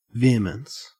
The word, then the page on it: vehemence: Australian English